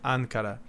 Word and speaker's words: Ankara: I clicked on the listen button and pronounced it Ankara.